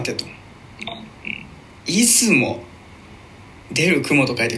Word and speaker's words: 出雲: 出る雲と書いて出雲って読んじゃう